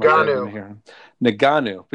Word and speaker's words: Ngannou: And the first of those being the recent bout between Francis Ngannou. Now, I never say that right. I always say it different. Ngannou.